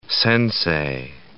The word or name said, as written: sensei